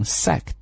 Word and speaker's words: sacked: 所以这个单词的读法呢，要读成 sacked。 OK，不要读成 sacked，也不要读成 sacked 啊，这些都不对，所以在这个地方注意一下这个 sacked 的读法。